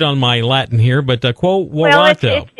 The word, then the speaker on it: Quo Warranto: Quo, is it pronounced Quo Warranto? – Yeah. – Is that how you... – Quo Warranto. Yeah, it's weird. It's Quo Warranto.